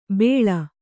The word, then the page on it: ಬೆಳ್: beḷ